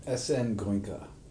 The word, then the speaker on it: Goenka: S.N. Goenka. – Goenka, who passed away in 2013.